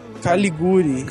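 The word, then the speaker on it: Caligiuri: Tem, tem um cara muito bom que eu gosto, que é o, o alemão Caligiri, né? – Caliguri. – Caliguri. É, é um I e um U, não é? é Caliguri.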